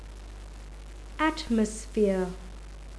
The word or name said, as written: atmosphere